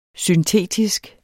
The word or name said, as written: syntetisk